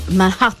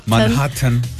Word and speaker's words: Manhattan: Manhattan. – Manhattan. L'hai detto bene.